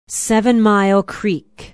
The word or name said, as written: Seven Mile Creek